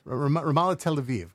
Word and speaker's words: Ramallah Tel Aviv: That's where Ramallah is, Tel David Broza, and also, excuse my pronunciation of this, Ari... – Ramallah Tel Aviv? – Ramallah Tel Aviv, correct.